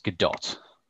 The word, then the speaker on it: Gadot: Next up is, I always get her name wrong, Gal, is it Gadot or Gadot? – I am reliably informed it's Gadot.